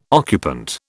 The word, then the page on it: occupant: British English